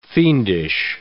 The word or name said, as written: fiendish